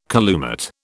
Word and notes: calumet: British English

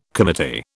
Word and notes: committee: British English

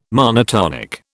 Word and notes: monotonic: British English

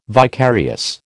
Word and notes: vicarious: American English

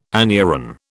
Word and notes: anuran: British English

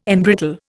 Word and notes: embrittle: Indian

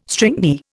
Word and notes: stringy: Indian

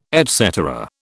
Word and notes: et cetera: British English

